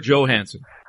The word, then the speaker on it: Johansson: Albert Johansson, is it Johansson or Johansson? – Johansson. – All right. I never know if I'm pronouncing the J as a J, as we do here, or like a Y.